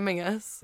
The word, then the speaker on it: Menzies: It's spelled M E N Z I E S. Right, okay. So in Scotland, you say Mingus. – Yeah. – But it's spelled Menzies. – Oh. – Scots say oh, Mingus. Oh, interesting. – Which is how it should be said.